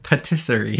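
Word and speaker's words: pâtisserie: He didn't have to go far and stumbled into a pastorie. – Patisserie? How do you say it? – Patisserie. – Patisserie.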